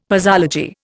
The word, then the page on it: posology: Indian